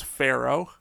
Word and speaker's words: Farao: So this is Pharaoh. It's spelled F-A-R-A-O.